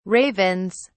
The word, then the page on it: ravens: British English